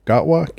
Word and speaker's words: Gatwa: Let me spell it for you. N-C-U-T-I. That's his first name. And G-A-T-W-A. Gatwa. Gatwa?